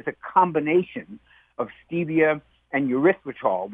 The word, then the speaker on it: erythritol: What, malitol, erythritol. Is that how you pronounce it? I think, mm-hmm. – Erythritol. – Erythritol.